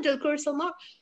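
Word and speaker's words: Dulquer Salmaan: That's right, Dulquer Salman.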